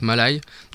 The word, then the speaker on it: Malaï: Toujours le vendredi soir, Malaï, ouais, ça Malaï. Se prononce Malaï. Donc en fait c'est un groupe, ils font du rock, chanson française rock, assez dynamique.